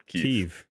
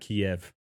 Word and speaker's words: Kyiv: That's how you actually pronounce it. – Kyiv, not Kiev.